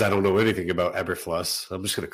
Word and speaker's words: Eberflus: I don't know if that's pronounced right. – I think it's Eberflus. – Eberflus. That sounds much better.